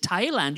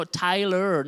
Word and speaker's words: Tyler: I have to be careful how I pronounce that because I said we're going to do a workshop in Thailand, and I don't pronounce the R just as much as you guys do. And so everybody said, Thailand, you're flying to Thailand this weekend? No, Tyler, not Thailand. Tyler, Texas.